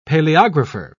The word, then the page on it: paleographer: British English